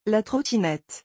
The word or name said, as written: La trottinette